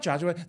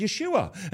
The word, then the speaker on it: Yeshua: It's not Joshua. That's not how they said it. They said Yeshua, and I have to say in the name of Yeshua.